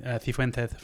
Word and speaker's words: Cifuentes: you could say, well, I speak South American Spanish, and that comes with a soft C rather than the th sound. So George speaks Argentine Spanish, and that's why he's going to be saying Cifuentes.